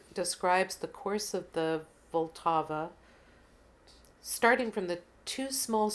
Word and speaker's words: Vltava: and I found it very interesting because it's Vltava is the Czech way of pronouncing this.